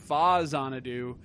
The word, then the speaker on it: Faxanadu: And, of course, the most popular version of this game is Faxanadu, as it's better known. Yeah. But it's actually pronounced Fazanadu because it's Famicom Xanadu, which I've probably talked about on an episode or two because I like to talk about it a lot.